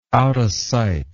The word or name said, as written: outasight